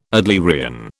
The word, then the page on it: Adlerian: British English